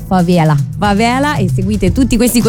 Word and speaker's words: favela: perché non dice favela come diremmo noi altri italiani, lei essendo mezza brasiliana dice favela.